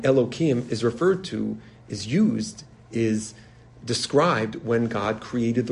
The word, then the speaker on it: Elohim: It's referred to, God is referred to in source here in the verse 13 as Aleph Lamed Hey Yud Mem, which is pronounced with a hey, but we'll pronounce it Elohim, not to say the name of God for no reason.